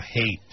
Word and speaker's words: Haight: I know Haight Street isn't spelled like hatred, but... – H-A-I-G-H-T.